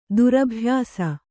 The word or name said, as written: ದುರಭ್ಯಾಸಿ